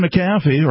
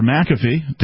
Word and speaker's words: McAfee: Tech titan John McAfee, or McAfee, depending on how you prefer to pronounce his last name, indicted for tax evasion and awaiting extradition as he was arrested in Spain.